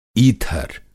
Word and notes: ईथर: Hindi

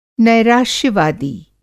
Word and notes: नैराश्य-वादी: Hindi

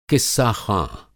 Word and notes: क़िस्साख़्वाँ: قصہ خواں

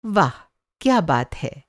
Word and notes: वाह: واہ

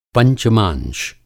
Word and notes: पंचमांश: Hindi

